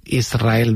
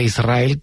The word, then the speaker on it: Israel: Y de diminutivo, de chiquitito, ella quería, porque Israel creo que le sonaba muy grande, ¿no? Israel, ¿no? – Y la S con la R es difícil de pronunciar.